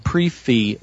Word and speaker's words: prix fixe: They call it a pre-fee menu. It's spelled P-R-I-X-F-I-X-E. – Yes, you pronounced it right.